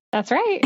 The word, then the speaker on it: Enneagram: Was I pronouncing it right? Enneagram? Is that right? – That's right. Yes.